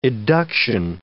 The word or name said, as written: eduction